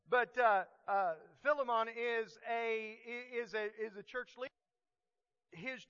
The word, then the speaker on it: Philemon: I wanted you to take note of the fact that Philemon, or if you're from the from Appalachia like me, we say Philemon. It actually sounds like filet mignon if you pronounce it correctly. Philemon.